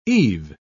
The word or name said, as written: eve